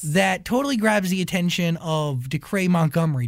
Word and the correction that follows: Dacre: Did I say his name right? – Dacre? – Decray? – Wow. No, it's Dacre.